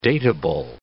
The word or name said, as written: dateable